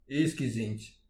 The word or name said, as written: iskis int